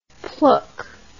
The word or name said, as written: plouk